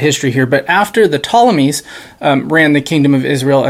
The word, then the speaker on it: Ptolemies: And so at first, one of those four kingdoms was the kingdom that ruled over the land of Israel, and that was the Ptolemies, with a silent P at the beginning there.